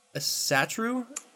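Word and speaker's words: Ásatrú: Okay, so this one, in essence, this individual performs ritual with, I'm going to say this wrong, Asatru? – That's how I pronounce it, sure.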